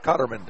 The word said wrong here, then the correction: Cotner: Cotner caught him. – Cotner, that's the one.